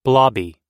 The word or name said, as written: blobby